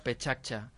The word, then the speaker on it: Pecha Kucha: Sí, de fet, Pecha Kucha és una expressió japonesa que de fet ahir ens vam assabentar que es pronuncia Pechacha.